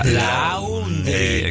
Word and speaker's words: laundry: L-A-U-N-D-R-Y. – Laundry. – Exacto. Se pronuncia laundry. Todo diptongo que en inglés es A-U se pronuncia como O. Es como la marca del auto Audi.